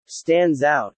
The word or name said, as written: stands out